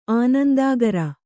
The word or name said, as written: ಆನಂದಾಗರ